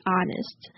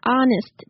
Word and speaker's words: honest: I'm a little skeptical on this point, to be honest. – To be honest，这边的 honest 记得 h 不用发音哦，然后 o 是念 r 的音 r。